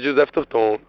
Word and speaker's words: Joseph Tourton: Sabe, qualquer pronúncia tá valendo, assim, mas a que a gente adotou primeiramente, assim, foi a banda de Joseph Turton.